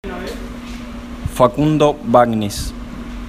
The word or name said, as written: Facundo Bagnis